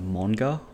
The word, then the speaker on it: manga: I generally tend to lead with the Japanese pronunciation of manga.